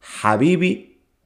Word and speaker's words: Habibi: Habibi es con Ha. Normalmente se escribe con H, sí, pero Ha, Ha. Ha es una letra que sale de la garganta.